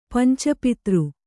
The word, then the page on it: ಪಂಚಪತ್ರ: panca patra